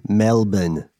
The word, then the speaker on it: Melbourne: Am I saying that correctly? Melbourne. – Yeah, yeah, that's right.